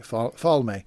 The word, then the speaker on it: Falme: I was saying Falme in my head for four books the last 2 weeks. It's Falm. It's Falm.